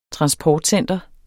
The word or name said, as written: transportcenter